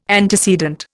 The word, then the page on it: antecedent: Indian